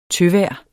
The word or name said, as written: tøvejr